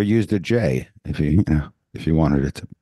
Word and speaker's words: GIF: It said he called it a GIF with a soft G. Choosy developers, he said, choose Jif.